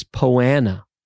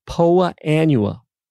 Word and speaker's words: Poa annua: I said, that's a proper pronunciation, Hank, because everyone says poana. Well, this weed is actually, it's two words, poa, P-O-A, then annua, A-N-N-U-A, poa annua.